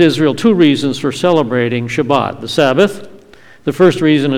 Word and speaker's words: Shabbat: The word Shabbat is how you say the Hebrew word in the Sephardic Jewish accent. You'll hear it also pronounced as Shabbos in the Ashkenazi Jewish accent.